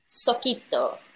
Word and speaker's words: Sokoto: Am I saying that not right, Sokoto? – I mean, I've always pronounced it Sokoto, but like, I mean, I could be wrong as well. So let's have a vote. How would you like to pronounce it? – Sokoto. – I think that one sounds the best.